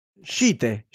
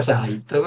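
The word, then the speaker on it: Scythe: Scite, Scite, Scite. – Credo si dica Sight, però vabbè.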